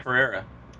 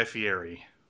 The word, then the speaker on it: Fieri: What about Guy Ferreira? – No comment yet from Guy Fieri. – Okay. – Which is how you pronounce his last name.